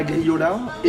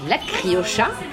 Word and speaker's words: criolla: Avec 2 sauces, donc la griola et la chimichurri. – Alors, faut savoir que les Argentins prononcent le che et pas le ye, donc on dit la criocha.